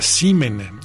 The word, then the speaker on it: C-Men: Vamos con esta banda que se llama Simen, se escribe C-Men, así como hombre en plural, o hombres más bien.